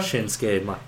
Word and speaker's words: Shinsuke Nakamura: Shinsuke Nakamura. I'm not sure if I'm saying it right. Shinsuke Nakamura? – Shinsuke Nakamura.